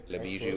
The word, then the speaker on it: Le Bijou: Le Bijou. It's French. Yes. It's not Spanish.